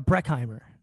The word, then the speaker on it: Breckheimer: And then in third place is our very own Erica Brecken. I say her name wrong. Like, luckily, I did not say it wrong on the Black Belt Spectacular. So Erica Breckheimer, right? I add an N in there.